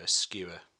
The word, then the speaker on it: Skua: And then I also kind of put a bit out under my own alias, which is Skua, which is spelled S-K-U-A, like the bird,